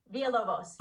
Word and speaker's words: Villalovos: Villalovos.